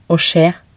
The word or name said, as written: å skje